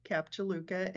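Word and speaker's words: Cap Juluca: Then after that, you became chief financial officer of Belmond Cap Jaluka. I don't know if I pronounced that right. – You did, yes, Cap Jaluka.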